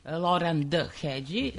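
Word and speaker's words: Lóránd Hegyi: Loran Hedži, koji je Loran D. Hedži, tako se pravilno izgovara, koji je bio više puta gost u Beogradu, mm-hmm.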